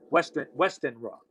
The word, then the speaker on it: Westenra: You have Arthur Holmwood, who's, there's this woman, Lucy Westerner, Westenra, that's how you pronounce it.